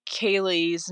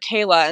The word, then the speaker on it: Kaylee: And the judge actually mispronounced Kaylee's name and called her Kayla. And so the family was very, very upset by this. I remember they were sitting to the right of me, and I remember them saying, "We traveled to be here. This has been horrible for us. The least he could do is pronounce her name correctly."